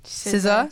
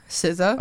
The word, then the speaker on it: SZA: SZA. – SZA. That's how you say it? – SZA.